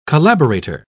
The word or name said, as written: collaborator